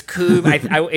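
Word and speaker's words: Kubb: It's kind of in, like, I think you're mispronouncing that. – No. It's Coob. It's, like, Norwegian or something.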